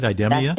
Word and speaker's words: IDEMIA: IDEMIA? – That's it.